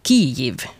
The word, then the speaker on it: Kyjiv: Huvudstaden Kiev ska nu utstavas K-Y-J-I-V och det ska uttalas Kiev med betoning på första stavelsen.